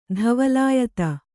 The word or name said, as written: ಧವಲಾಯತ